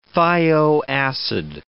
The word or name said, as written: thio acid